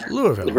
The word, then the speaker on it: Louisville: And you know how to pronounce Louisville. It's not Louisville. – Right. – It's Louisville. – Right. – I mean, Louisville. – No, really, it is. That's the way it's pronounced.